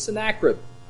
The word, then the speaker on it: Sennacherib: Sennacherib or Sennacherib? It's Sennacherib. It's always Sennacherib. If I ever say Sennacherib again, just raise your hand.